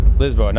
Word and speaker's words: Lisboa: And you call it Lisboa, right? – Lisboa. – Lisboa, not Lisbon.